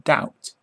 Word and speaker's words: doubt: B must not be heard in doubt.